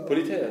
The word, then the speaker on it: Politeia: Politeia? Und wie spricht man dir? – Politär ist richtig. Politär ist richtig.